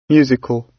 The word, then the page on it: musical: British English